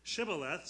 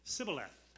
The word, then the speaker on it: Shibboleth: And the Ephraimites could not pronounce the word Shibboleth, so they would say Sibboleth.